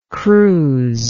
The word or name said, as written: cruz